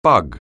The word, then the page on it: bug: anglais